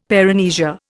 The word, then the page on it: paramnesia: Indian